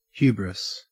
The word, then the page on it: hubris: Australian English